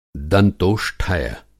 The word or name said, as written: दंतोष्ठय